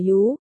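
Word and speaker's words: Yú (鱼): Đại Ngư, là từ đồng âm với Dư trong tên của Dư Mục Ca, Ngư, Yêu Pinyin đọc là Yú, Dư, Yêu Pinyin cũng đọc là Yú.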